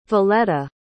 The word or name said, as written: voleta